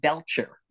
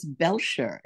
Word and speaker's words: Belcher: They were saying Belcher, like a burp. I went, ooh, what an ugly name. But it's not Belcher at all. It's Belcher. It's actually Belcher.